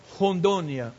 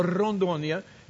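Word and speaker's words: Rondônia: It's called Hondonia or Rondonia, if you prefer that pronunciation. Both are correct.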